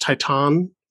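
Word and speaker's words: Titane: Uh, he says it's a tie between Titan. I've also heard it pronounced Titan, which is probably the French pronunciation, and uh, the Green Knight.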